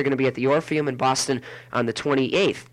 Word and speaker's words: Orpheum: Is that Euphorium? Is that how they say it? Or Orpheum. There it is.